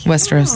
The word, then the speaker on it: Westeros: Westeros.